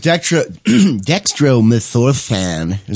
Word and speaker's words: dextromethorphan: Now DXM is dextromethorphan.